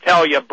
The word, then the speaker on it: Helje: Her name is spelled H-E-L-J-E. Helya, pronounced Helya Brand Kaskel,